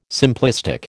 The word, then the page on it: simplistic: American English